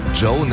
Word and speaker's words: Djo: Joe nennt er sich. Und es ist Joe Keery, spielt in Stranger Things. – Ja, und Joe schreibt man D-J-O.